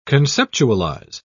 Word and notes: conceptualise: British English

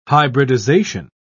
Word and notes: hybridisation: British English

